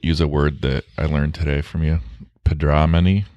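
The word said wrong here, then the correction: potamodromy: Is that how you say it? – Patadromy.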